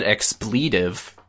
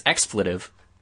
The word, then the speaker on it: expletive: I mean, you just said expletive. It's expletive.